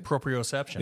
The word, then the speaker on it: proprioception: Linking back to our previous conversation about proprioception. Did I say that right? – Proprioception. – Yeah, proprioception.